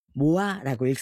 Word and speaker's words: MOA: 팬덤명 MOA. 모아라고 읽습니다.